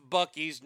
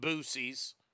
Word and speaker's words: Buc-ee's: And it's pronounced Buc-ee's, not Boosie's. Fucking idiots on social media calling it Boosie's.